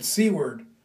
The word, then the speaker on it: Seward: And people pronounce it Seward. I'm going to Seward. That's a good Seward. S-E-W-A-R-D.